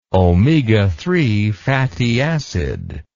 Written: omega minus particle

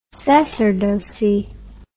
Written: sacerdocy

